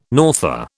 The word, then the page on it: norther: British English